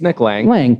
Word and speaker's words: Nick Laing: It's Nick Laing.